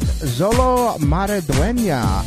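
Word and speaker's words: Xolo Maridueña: And if you are celebrating your birthday today, you share your birthday with Zolo Maradueña, a.k.a.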